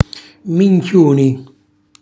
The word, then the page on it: minchiuni: Calabrese